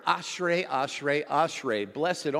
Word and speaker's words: Ashrei: This word, by the way, is pronounced, it's easy, Ashrei. Everybody say Ashrei.